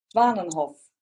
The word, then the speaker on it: Zwanenhof: Zwanenhof.